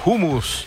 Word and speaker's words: hummus: Es hummus, esa es la pronunciación. – Así es, hummus. Hummus. – La J, la H en árabe se lee como una J suave.